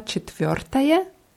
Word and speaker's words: четвертое: Sag mal четвертое. – Четвертое. – Ja, beide Rs müssen ganz deutlich hörbar sein.